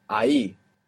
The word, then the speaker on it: ahí: The word for there is ahí. Ahí. Spelt A-H-I. And there also exists ahí spelt with double L. There's a tiny, even imperceivable difference between I, A-H-I, and I, A-L-L-I.